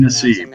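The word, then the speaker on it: Nassib: God, I'm Ryan Nasib. Really. Yes, you are pronouncing great.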